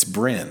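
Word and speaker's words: Brenne: it's French, so it's Brin. And I was like, oh, sorry, yes, because I kept calling it Brené, but it's Brin, and so I can't shame anyone on languages.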